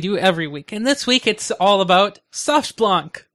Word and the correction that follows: SoftBank: I mean SoftBank.